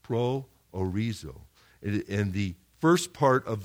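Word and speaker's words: proorizō: And the second part of the word is horizo. We get the word horizon from it. Horizo, horizon. You guys get the transliteration there, okay? So this word pro orizo is only used 6 times in the New Testament.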